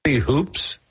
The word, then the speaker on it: Hoops: Would you mind, and I want to ask you about the pronunciation of the last name, is Hoops? Is that the name? – Yes. Like a hula hoop.